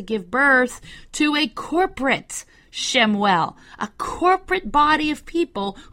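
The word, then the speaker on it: Shemuel (שמואל): Shemuel is how you say Samuel in Hebrew.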